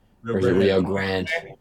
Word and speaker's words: Rio Grande: Rio Grande.